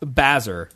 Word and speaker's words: Basehor: Base whore. That's how it's spelled. B-A-S-E-H-O-R. But I think it's pronounced Bazer.